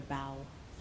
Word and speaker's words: bough: And I love the way in the translation into English, the bare bough, that the way that even though it's spelled B-O-U-G-H to mean the branch of a tree, but the feeling of a bough, like a bough.